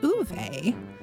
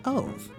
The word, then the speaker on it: Ove: Yeah, but she said she listened to the audiobook and it was Uve.